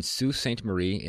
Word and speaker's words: Sault Ste. Marie: Sault Ste. Marie, and yes, I pronounced that correctly because I looked it up, which is in Ontario.